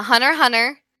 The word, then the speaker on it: Hunter x Hunter: Yeah, Hunter Hunter. – Is he a bad guy or a good guy? – The X is silent.